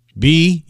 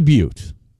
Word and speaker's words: Butte: Butte. How would you pronounce that name as you were driving into Montana? – But. – You'd say but. But Montana. – But Montana. – Well, it's actually Butte.